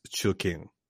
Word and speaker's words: Chu King: So her actual first name is Chu King, which she pronounces. She's like, it's easy to say. You say Chu and King, Chu King.